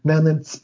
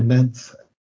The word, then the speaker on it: Nenets: And it's the same, you know, of Nenets people, or Nents, as we should really pronounce them,